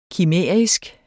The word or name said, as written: kimærisk